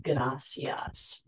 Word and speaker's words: gracias: The pronunciation of the single R in Spanish, a motion called the alveolar tap, makes the word gracias roll off your tongue.